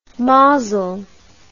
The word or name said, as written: maazel